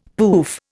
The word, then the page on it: pouf: Indian